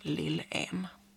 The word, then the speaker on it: Lillen: För dem är det ett norskt släktnamn som ska uttalas Lillen.